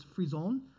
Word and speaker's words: frisson: I still hold that it's not pronounced the French way, but I'll do it the French way. It's frisson is what it is. The other stuff said it was frisson, but F-R-I-S-S-O-N.